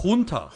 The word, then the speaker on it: Junta: Is it Junta or Yunta or Yunta? – Junta.